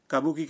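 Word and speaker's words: کابوکی (歌舞伎): کابوکی کا درست تلفظ ہے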